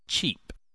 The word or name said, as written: cheap